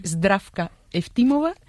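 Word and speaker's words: Zdravka Eftimova (Здравка Ефтимова): Ayúdanos a pronunciar los autores. – A ver, Georgi Gospodinov, sí. Zdravka Eftimova y Vera Mutavcheva.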